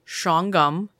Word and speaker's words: Shawangunk: Another haunted location is nestled in the foothills of the, now it's spelled Shawangunk, and we called your mom to confirm, and I put it in parentheses, it's pronounced Shawangum Mountains in the small town of Napanock, New York.